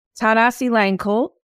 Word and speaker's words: Thanasilangkul: And this week, I am with my new friend, Zach, and I'm going to say it right, Thanasilangkul. How'd I perfect. – Do? – Oh, perfect. You.